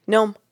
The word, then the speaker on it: Noam: Gnome. – Like as in gnomes, like the little things that you're drawing? – Oh, but it's spelled N-O-A-M.